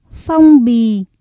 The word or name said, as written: phong bì